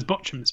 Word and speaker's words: Bochum: Yeah, I used to call them Bochum, and then I found out it was Bochum, so I changed it.